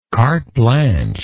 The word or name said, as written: carte blanche